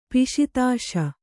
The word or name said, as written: ಪಿಶಿತಾಶ